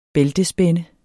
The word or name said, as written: bæltespænde